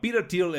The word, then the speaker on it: Thiel: Se llama Peter Thiel, el, el autor, Peter T-H-I-E-L. Voy a empezar a decirlo porque luego dicen que, no se me entiende bien cómo se dice el nombre. Intento pronunciarlo como sé, como se llama la persona, básicamente, como le gustaría que le llamaran en su casa.